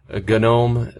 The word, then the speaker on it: GNOME: GNOME, it's pronounced with a hard G, G-N-O-M-E, GNOME.